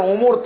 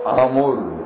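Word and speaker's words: আমর (عمرو): ওখানে আইন মিম রে তো আছে, ওর সঙ্গে আরেকটা অক্ষর বেশি আছে, সেটা হলো ওয়াও, এতে উচ্চারণ উমর থাকে না, এটার আরবি উচ্চারণ হলো আমর।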